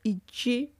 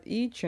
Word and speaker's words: Ичи: Короче, фильм называется «Ичи хоть все его ошибочно называют Ичи, но опять же, я учила якутский, милые мои, мне видней, как правильно ставить ударение в якутских словах, блин.